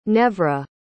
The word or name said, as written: Nevra